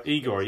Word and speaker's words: Igor: Igor. I can never pronounce it right. I mean, the Brazilians will tell me. – Igor, yeah.